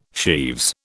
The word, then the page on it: sheaves: British English